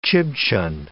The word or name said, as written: Chibchan